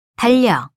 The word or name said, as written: 달력